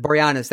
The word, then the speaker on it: Boreanaz: Boreanaz.